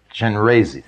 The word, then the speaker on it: Chenrezig: One of them is Chenrezig. Does anybody know how to pronounce it? – Chenrezig.